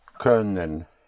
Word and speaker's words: können: very important to bring out the sound of an Ö. Können. Like, think of fur, of sir. That Ö. – Können.